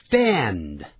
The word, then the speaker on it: stand: 像 stand, 站起来 ,s-t-a-n-d, s-t-a-n-d, 我们通常用它还再加一个 up,up, stand up, 合在一起这是分开的两个字，但念的时候连在一起念 stand up stand up，而不念成 stand up 就很难念，所以呢在后头有音，我们也是发成 d stand stand。